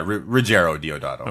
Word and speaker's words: Ruggero Deodato: We are covering today Raiders of Atlantis from Ruggero Deodato. I hope I said that right. Did I? – Yeah, Ruggero Deodato.